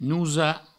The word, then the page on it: nosar: nousa